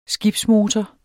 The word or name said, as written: skibsmotor